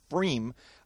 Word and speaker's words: pFriem: Okay, this is from Freem, which is spelled P-F-R-I-E-M, which is just a fun little thing. It's the Pint Defiance IPA out of Hood River, Oregon. – That's kind of like Price Pfister, right? The Fabulous Faucet with a funny name? – Yes, with the silent P.